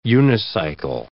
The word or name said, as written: unicycle